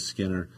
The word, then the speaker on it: Skinner: My last name is Skinner. You could pronounce it with a silent K.